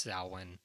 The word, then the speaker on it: Samhain: It looks like it's spelled Sanheim, but I think it's pronounced Samhain.